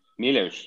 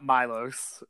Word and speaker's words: Miloš: Miloš. – Yeah, it's just like, it's not Milos or Milos, it's Miloš. It's like there's... – Oh, I thought it was Miloš.